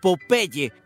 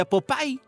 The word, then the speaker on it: Popeye: Bueno, es decir, que el nombre del marinero en español se pronuncia así como siempre lo hemos conocido, Popeye, pero en inglés se pronuncia Popeye.